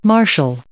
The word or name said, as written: marshal